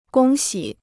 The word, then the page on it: 恭喜: gōng xǐ